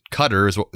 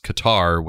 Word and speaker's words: Qatar: It's weird because when I worked at CNN, they told us our guidance was Qatar was called Qatar. You said Qatar is the actual proper pronunciation, but all the announcers throughout the whole thing are saying Qatar. So we're just going to go with Qatar.